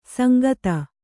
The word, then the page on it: ಸಾಂಗತೆ: sāngate